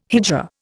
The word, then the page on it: hijra: Indian